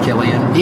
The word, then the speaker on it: Cillian: It's pronounced Cillian. – Yeah. And some people got so mad at me on some message board just screaming about how, get it straight, it's Cillian.